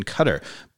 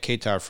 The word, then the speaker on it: Qatar: So the correct pronunciation of the country has always been Qatar, but everybody in the U.S. has always been calling it Qatar for years.